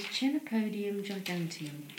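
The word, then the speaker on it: Chenopodium giganteum: I mean, its Latin name is Chenopodium gigantium.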